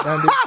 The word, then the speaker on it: Dundee: That's Dundee, right? That's how you say his name? That was Ali's trainer. – Oh, Dundee. – Yeah, Dundee.